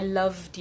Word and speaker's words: loved: I laughed because I loved you. Laughed es con T, love es con D.